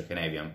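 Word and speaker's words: Cynefin: C'è un modello interessante che mi è capitato di studiare di recente, partecipando al progetto municipale di Sin Transition che si chiama modello Kenevian, è scritto Sinefin, insomma, si pronuncia Kenevian perché il tizio che se l'ha inventato è gallese, se non sbaglio,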